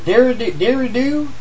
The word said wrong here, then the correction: didgeridoo: is that it? Didgeridoo.